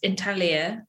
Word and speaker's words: intaglio: Yeah, so intaglio, well, it's intaglio if you're Italian. I guess you don't pronounce the G.